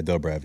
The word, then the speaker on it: Dobrev: Yeah, Dobrev, yeah.